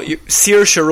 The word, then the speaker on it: Saoirse: Also, Saoirse Ronan is how you pronounce it. – So that they have to say the name on stage, Saoirse.